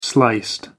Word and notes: sliced: American English

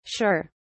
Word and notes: shir: British English

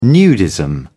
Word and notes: nudism: British English

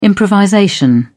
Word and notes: improvisation: British English

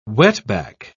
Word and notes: wetback: British English

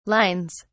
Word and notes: lines: British English

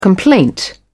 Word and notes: complaint: British English

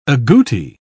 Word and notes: agouti: British English

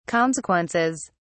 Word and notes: consequences: British English

